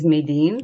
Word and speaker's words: Madene: I hope I've got your name right and all the designations and all the titles. – First of all, you're very close. My name is Madene.